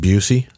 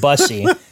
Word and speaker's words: Bussey: Isn't it pronounced Busey? – No. So, um, who made that mistake? someone made that mistake. They said Barry, Barry Busey. I'm like, it's not Gary Busey's brother. It's Barry Bussey.